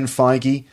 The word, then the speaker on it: Feige: Okay, let's talk about Kevin Feige. Is that how you say Feige? Is that how you say his name? – Yeah. – Kevin Feige.